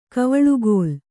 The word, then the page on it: ಕವಳುಗೋಲ್: kavaḷugōl